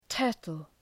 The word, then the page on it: turtle: ‘tɜ:rtəl